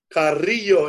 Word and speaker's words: Carrillo: My name is Frankie Carrillo. – And how do you say that again? Frankie Carrillo? – Frankie Carrillo is C-A-R-R-I-L-L-O.